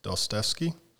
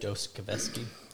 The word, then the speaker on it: Dostoevsky: So that's one of the things he mentioned in the book, and I think it was Dostoevsky. Pronunciation. Nailed it. I feel like yeah. Nailed it. – Nailed so, it. Dostoevsky.